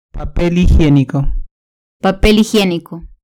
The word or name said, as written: papel higienico